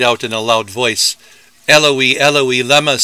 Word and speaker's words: Eloi: Eloi has three syllables, rhyming with the names Eloise and Heloise.